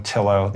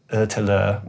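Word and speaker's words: Ötillö: Americans would call it Otillo. The Swedish pronounce it Otillo, which means island to island.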